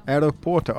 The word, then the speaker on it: Aeroporto: Yeah, well, I was calling it the Giro di Calcio, but maybe it's the Giro di Aeropuerto. – That's Spanish, but close. – What is it in Italian? – Aeroporto. – Aeroporto. Oh, okay. Sorry. I got the final syllable incorrect.